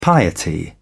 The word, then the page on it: piety: British English